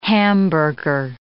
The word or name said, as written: hamburger